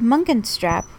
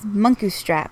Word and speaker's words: Munkustrap: And then you said Munkenstrap, which I'm pretty sure never had an N in it. It is Munkustrap.